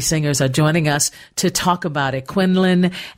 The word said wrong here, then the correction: Quillen: Is it Quillen? – Yes. – Oh, I'm so sorry I mispronounced it.